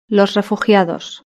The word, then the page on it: los refugiados: Spain Spanish